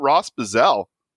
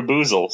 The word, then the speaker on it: Buzzell: But we've got Ross Buzzell. – Hey, how you doing? – Did I pronounce it anywhere close to in the right hemisphere? – That was actually perfect. Typically, I get Buzzell or Boozle, so thank you.